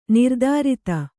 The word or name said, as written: ನಿರ್ದುರಿತ